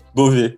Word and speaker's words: Beauvais: Só que você não chega em Paris, você chega em Beauvais, como é que fala? Beauvais. – Beauvais, Beauvais. Uhum.